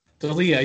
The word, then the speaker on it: D'Elia: D'Elia, yeah, how he had accusations.